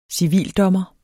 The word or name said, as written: civildommer